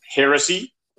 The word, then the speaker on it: heresy: That is pronounced heresy. It is the crime of departing from orthodox thinking in religion.